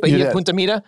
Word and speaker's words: Bahia Punta Mita: Did I say that right? Bahia Punta Mita? – Yeah.